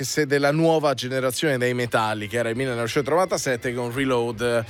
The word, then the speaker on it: Metallica: Marian Faithful nella chiusura poi di The Memory Remains dei Metallica, perché voglio ricordare che si pronuncia così,